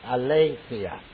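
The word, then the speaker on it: aletheia (ἀλήθεια): A-L-E-T-H-I-A. A-L-E-T-H-I-A, and it's pronounced aletheia. Let's say it all together.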